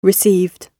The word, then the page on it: received: British English